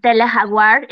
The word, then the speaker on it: Tele Jaguar: And I would also encourage people to check out Tele Jaguar. It's spelled T-E-L-E like Telesur, but Tele-Jaguar. So Tele Jaguar.